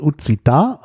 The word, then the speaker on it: occitan: Also stand dort das Wort, also richtig ausgesprochen, Occita, also auf Deutsch würde man vielleicht sagen Occitan, also Occitan geschrieben, O-C-C-I-T-A-N. Und das ist ja der Name einer Sprache, also in der Sprache selber Occita, die in Südfrankreich gesprochen wird und so ein bisschen ganz am Rand im Piemont in Norditalien, wo ja auch dieses Produkt herkommt.